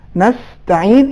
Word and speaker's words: نستعین: یا مثال کے طور پر دیکھتے ہیں قَدْ سَمِعَا، خود سورہ حمد میں ایک کلمہ ہے نستعین، ممکن ہے بعض افراد جلدی میں اس کو ادغام کے ساتھ تلفظ کریں نستعین، یہ صحیح نہیں ہے. نستعین اس میں ادغام نہیں ہے.